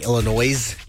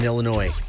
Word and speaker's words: Illinois: I know a lot of people like to mispronounce Illinois by saying Illinois. – Fair. – There's no Illinois in Illinois.